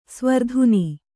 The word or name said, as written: ಸ್ವರ್ಧುನಿ